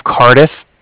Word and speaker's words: Cardiff: Okay. – And then I'm calling with the Bank of Cardiff. That's spelled C-A-R-D-I-F-F, mm-hmm. As in Frank, so it's like car and then different.